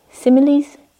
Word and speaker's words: simile: And the Buddha spoke a lot about the hindrances, and he's got some really beautiful similes. I learnt this word today. I didn't know how to pronounce it. And then I found out it was simile, which is really cute.